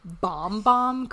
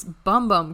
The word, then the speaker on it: Bum Bum: Yes, apparently you pronounce it bomb bomb cream, but I'm like, bitch, it's bum bum cream. Like, that's how it's spelled.